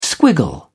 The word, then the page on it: squiggle: British English